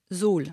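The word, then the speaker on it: Suhl: Vous revenez de Soul. Soul, ça, est-ce que je prononce bien? – Zoul, oui.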